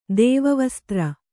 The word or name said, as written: ದೈವವಿಸೂತ್ರ